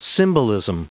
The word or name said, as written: symbolism